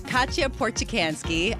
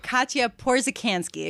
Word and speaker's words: Katia Porzecanski: I want to bring in Katia Porzecanski. I mispronounced her name, even though we had just been talking about it. Katia Porzecanski, excuse me, Bloomberg News reporter, who wrote a fantastic story on the Bloomberg.